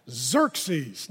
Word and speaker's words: Xerxes: Okay, you got to say it like that. Xerxes.